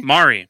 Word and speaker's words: Maury: Maury.